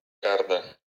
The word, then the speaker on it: Кардо: Как правильно ставить ударение, кардо или кардо? – Кардо.